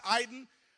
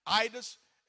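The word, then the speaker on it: eidos (εἶδος): You can pronounce it eiden, eidos. It's E-I-D-O-S or E-I-D-O at the end.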